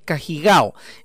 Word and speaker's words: Cajigao: Ahí está la explicación de Pablo Milán respecto de la función de Francis Cajigao. ¿eh? Así se pronuncia, según dijo el propio Pablo Milán. ¿eh? Francis Cajigao es londinense de origen español, o sea, es inglés con ascendencia española.